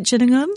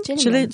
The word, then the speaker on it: Gillingham: Did I say that right? Gillingham? – Gillingham.